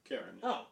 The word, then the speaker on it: Karen: Is it pronounced Karen? – Karen. – Oh, Scottish.